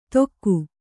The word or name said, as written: ತೊಕ್ಕು